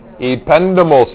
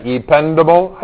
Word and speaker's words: ependymal: Ependymal cell. Yeah, ependymal. I usually say it right. There might be other ways of saying it, but ependymal is a good way to say it.